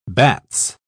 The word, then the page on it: bats: British English